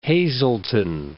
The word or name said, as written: Hazleton